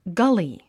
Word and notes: gully: gʌ’li; ガリィ

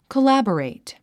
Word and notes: collaborate: kəlǽbərèit; コラボレイト

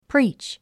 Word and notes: preach: príːtʃ; プリーチ

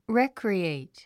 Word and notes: recreate: rékrièit; レェクリエイト

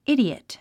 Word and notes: idiot: ídiət; イディオット

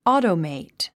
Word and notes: automate: ɔ́ːtəmèit; オゥトメェイト

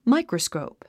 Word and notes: microscope: máikrəskòup; マイクラスコウプ

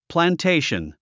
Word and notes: plantation: plæntéiʃən; プランテイション

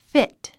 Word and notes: fit: fít; フィット

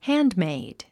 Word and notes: handmade: hǽn(d)méid; ハンドメイド

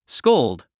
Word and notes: scold: skóuld; スコウルド

